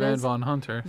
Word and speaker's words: Van Von Hunter: But then we have, I still don't know how to say it, Van Hunter Vaughn, or Van Von Hunter, I think it is. Van Von Hunter.